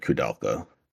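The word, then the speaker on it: Koudelka: But no, Kudulka, or how do you pronounce it? Kudelka? – I've always pronounced it Kudelka.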